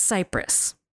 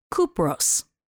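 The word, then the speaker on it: Kupros (Κύπρος): mined it on the island of Cyprus, which is not pronounced Cyprus. It's pronounced Kupros.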